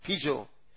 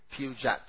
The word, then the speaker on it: Peugeot: How do you say it in Zambia? Pijo, pijo, But in Zimbabwe, the T is loud. Pujat. or Pijot.